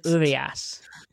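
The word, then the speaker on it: Urías: So how did you say that name? – I said it. So, and Urias. I said Urias.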